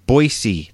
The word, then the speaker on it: Boise: You can look it up in Boise. That's how they say it out there, Idaho.